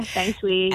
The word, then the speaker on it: Feng Shui: Now, I know a lot of people are very cynical about, how do you say it? Feng Shui, is it? – Feng Shui? Yeah, Feng Shui, yeah.